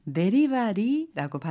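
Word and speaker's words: 데리바리라고 (デリバリー): 데리바리 데리바리 딜리버리를 일본어로는 데리바리라고 발음하면 되겠습니다. 부탁하다, 시키다, 이때 시키다는 음식점 등에서 주문하다 라는 의미로도 많이 사용하는데요.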